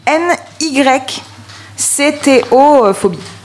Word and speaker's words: nyctophobie: Non, alors, la nycophobie. – Alors, attention. – Nycto, c'est quoi? – Ça s'écrit N-Y. C-T-O-phobie. – Ah oui, nyctophobie. – Ah ouais, comme nyctophobie.